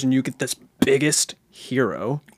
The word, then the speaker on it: Gyro: Is it Gyro or Euro? – Hero.